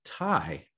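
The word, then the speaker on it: Thầy: Most of his followers called him T-H-Y, which I'm like, oh, they? No, it's pronounced Thai, which means teacher.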